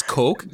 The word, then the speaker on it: kok: It's spelled K-O-K. People pronounce it the other way. – they pronounce it cock, and I think I've pronounced it that way, right? I mean, not thinking that it's cock, but it's coke. – But it's coke, yeah, okay. like the Coca-Cola coke.